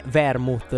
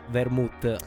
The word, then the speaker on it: vermouth: Ah, c'è una piccola correzione da fare sulla mia pronuncia, purtroppo è vermouth, non vermouth.